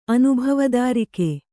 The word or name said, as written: ಅನುಭವದಾರಿಕೆ